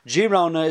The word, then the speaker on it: Girona: From this place, it means he's one of Girona, or Girona is the proper way of pronouncing it.